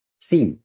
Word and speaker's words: theme: シーンっていうのは、日本語ではテーマと言っています。日本語ではテーマという発音にならないんですね。 T-H-E-M-E です。シーン。シーンです。